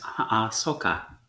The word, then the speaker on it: Ahsoka: Ahsoka is getting her own series. Ahsoka. Ahsoka. I keep. They. The way her name is spelled makes my dyslexia hurt. – Yes, Ahsoka.